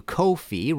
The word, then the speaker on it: Ko-fi: If you like this show and want to support future episodes, please go to Ko-fi, rhymes with no fee, .com.